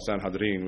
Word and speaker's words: Sanhedrin: Now remember, he was the youngest serving member, historically the youngest serving member on the Sanhedrin, which is the way you pronounce it, the Jewish Sanhedrin Council.